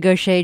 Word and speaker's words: Gauthier: The song Mary Gaucher just performed is on her new album, Trouble in Love. Her name, by the way, is spelled G-A-U-T-H-I-E-R.